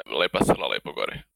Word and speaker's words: Лепа села лепо горе: Yeah, what I understand from the title, Lepa, Sela, Lepo, Gore, or how would you say that with the authentic accent? – Yeah, Lepa, Sela, Lepo, Gore.